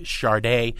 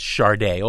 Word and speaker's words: Sade: Greg, I think a lot of people think Sade, and it is pronounced Charday, although it's spelled S A D E, and they think the Reagan Thatcher years.